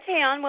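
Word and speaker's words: Tayaan: It's T-A-Y-A-A-N. I spell it out because it's pronounced Tayon, because a lot of people don't know how to pronounce it. It's Tayon. – Hi, Tayon.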